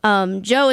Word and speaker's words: Djo: Joe is spelled D-J-O.